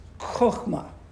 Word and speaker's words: chokmah: The word is pronounced in Hebrew. It's a rough breath sound. Is chokmah. Chokmah. It means wisdom.